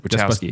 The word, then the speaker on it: Wachowski: Wachowski, that's it. Wachowski.